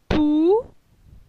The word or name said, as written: Pou